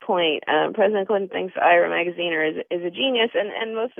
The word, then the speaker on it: Magaziner: You reported that Magaziner, am I saying his name right? Magaziner? – Yep.